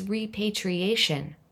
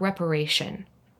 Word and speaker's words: repatriation: It's repatriation, not reparation.